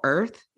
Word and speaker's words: Irth: The app is called Earth, like birth, but without the bias. I think that's what their slogan is. So without the B, so it's spelled IRTH.